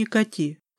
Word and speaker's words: Кати: Вначале сэр Энтот представил девушку всем своим домашним как ведьму по имени Кати с ударением на последнем слоге, на местный манер.